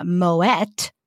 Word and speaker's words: Moët: Plus, Napoleon already had his favorite sparkling winemaker, Jean-Rémy Moët. Pronounce the T, because he wasn't French. He was Dutch.